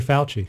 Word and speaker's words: Fauci: it goes back to Karen and I were talking this morning, Doctor, we couldn't say his name correct. We didn't, F-A-U-C-I. – Dr. Fauci. – Fauci. Thank mm-hmm.